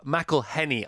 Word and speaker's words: McElhenney: It's Rob McElhenney. I say McElhenney because I drop my H's because I've always dropped my H's, but you don't.